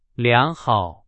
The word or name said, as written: 良好